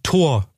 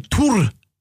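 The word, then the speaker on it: Thor: Also er ist, er ist quasi Tor oder Tur, wie er im dänischen Original ausgesprochen wird.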